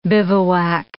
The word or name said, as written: bivouac